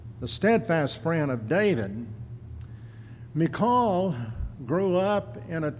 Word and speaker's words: Michal: Her name was, and I will spell it first, M-I-C-H-A-L. Everyone pronounces that Michael, but during her lifetime, no one ever said it that way. She would not have known what you were talking about if you had called her that. The proper Hebrew pronunciation of her name is Michal, which is just as easy to say as Michael. Michael's a boy's name. She was Michal, and that's what I'm going to call her the rest of the time tonight.